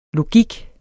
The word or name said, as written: logik